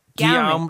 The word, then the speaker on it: Guillaume: Guillaume, like William.